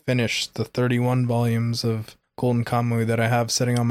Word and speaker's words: Golden Kamuy: Golden Kamuy.